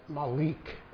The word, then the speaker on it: Malik: And the man invited to give the dedication speech was a man by the name of Charles Malik. Some pronounce it Malik, M-A-L-I-K.